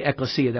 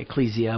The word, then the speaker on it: ekklesia: And the ekklesia, that's the Greek pronunciation, ecclesia, and we get Ecclesiastes. And you know, ecclesiastical and all those things like that. But the word ekklesia, E-K-K, is the way it's in the Greek in the transliteration.